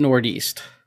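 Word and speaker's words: Nordeast: It's apparently how when the Norwegians were settling the upper Midwest, that's how they pronounced Northeast, was Northeast.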